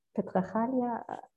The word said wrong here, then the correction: patriarchy: Aunt Chloe, you can patriarchy, yeah, patriarchy.